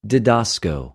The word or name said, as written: διδασκω